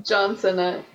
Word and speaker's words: John Cena: John Cena.